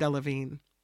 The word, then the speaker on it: Delevingne: It's spelled Delevingne, but I think it's pronounced Delevingne.